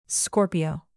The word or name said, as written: scorpio